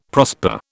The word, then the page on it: prosper: British English